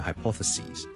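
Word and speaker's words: hypotheses: crises 呢个原因咧，系因为来自一个希腊文嘅一个 plural，咁所以咧，我哋就会咧 sis 变咗做 ses，仲有其他字都系咁嘅，譬如 hypothesis 呢个假设，hypothesis，hypothesis hypothesis， hypothesis 亦都系来自希腊文嘅一个字，so hypothesis。 Plural 咧，我哋系 s i s 转咗做 s e s，就变咗 hypothesis。记住个发音，OK？由 sis 转咗做 sees，你当好似系 c s e a 或 s e e 嗰个 c，跟住再加多个 s， so singular hypothesis， plural hypothesis， singular crisis， plural crises。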